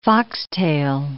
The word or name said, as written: foxtail